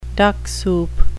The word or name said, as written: duck soup